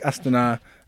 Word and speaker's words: Astana: Like Astana, the cycling team. – Yeah, like Astana. Notice I corrected your pronunciation sort of passive-aggressive way.